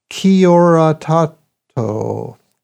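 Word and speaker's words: Kia ora tātou: By the way, the Maori greeting on that show is pronounced Kia ora tato.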